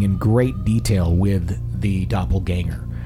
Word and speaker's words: doppelganger: Is a doppelganger, am I saying that right? – Yeah.